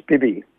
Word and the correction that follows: Spivey: It's, uh, it's Spivey. Oh, it's Spivey. Yeah, I got it wrong too, but um, it, yeah, it sort of rem- reminds with, you know, rhymes with Spidey as in spidey sense.